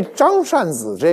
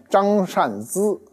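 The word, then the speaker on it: 张善孖: 这张善子这名啊，准确发音应该念张善资啊，但行里没这么念啊，都叫张善子，所以你念对了就是念错了，念错了就是念对了。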